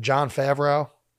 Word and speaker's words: Jon Favreau: Him and Jon Favreau. Is that how you say it? – Yes.